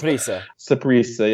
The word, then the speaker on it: Saprissa: Saprissa, yeah.